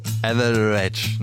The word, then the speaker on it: Etheridge: Etheridge, ne? So heißt die Frau. Melissa Etheridge, Frank Falkenauer, so spricht man sie aus.